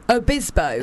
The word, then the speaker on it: Obispo: Bisbo is a great name. – Obispo, yeah, I know. – Obispo or just Bisbo? – Obispo. – Obispo. – Spelled with an O.